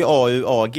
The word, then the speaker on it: AuAg: Jag har alltid uttalat det AUG-fonder, men det kanske är fel. – Nej, det är AUAG. Det kommer ju från teckningarna för guld och silver i periodiska systemet.